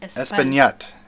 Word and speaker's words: Espagnet: I don't think you pronounced the G in that. – Espagnet. – Yeah, I would say that.